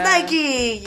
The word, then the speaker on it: Nike: It is Nike. Yes, the wings of Nike, the goddess of victory. – Yes, as my art history professor would pronounce it, Nike.